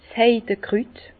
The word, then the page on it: 's Heidekrüt: Bas Rhin; Herrlisheim